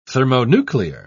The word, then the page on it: thermonuclear: British English